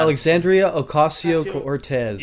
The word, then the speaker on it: Alexandria Ocasio-Cortez: how do you pronounce her last name? I'm always bad. – Alexandria Ocasio-Cortez. – Yes.